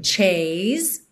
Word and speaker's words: chase: Thrilling chase. Termina o som no S com uma carinha de Z, o E final não é pronunciado, ok?